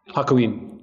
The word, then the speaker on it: Hakuin: Hakuin.